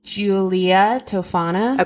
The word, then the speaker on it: Julia Tofana: Oh, and I was going to ask you how to pronounce this, and I forgot to do that. – Uh-oh. – Julia Tofana. – Okay, perfect.